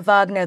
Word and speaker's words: Wagner: So I pronounce it Wagner because the the neo-Nazi who founded it was inspired by uh, the composer Wagner's professed Nazi sympathies.